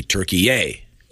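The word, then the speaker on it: Türkiye: And the pronouncer from the Associated Press is the traditional Turkey, followed by, in caps, Y-A-Y, which means that would be the emphasis, that syllable. So it would be Turkey-ay.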